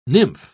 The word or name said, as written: nymph